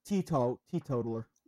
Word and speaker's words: teetotaler: I know he's also a Tito, or at least he said he's a Tito Taylor, so he doesn't drink, if that's how you pronounce it, Tito Taylor. – Teetotaler. – Teetotaler, okay. Teetotaler, that's good to know.